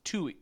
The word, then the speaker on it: Tuohy: It's Tuohy. It's like Chewy with a T. It's Tuohy.